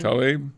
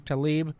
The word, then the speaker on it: Tlaib: Yeah, Rashida, how do you pronounce her last name? – Tlaib. – Tlaib.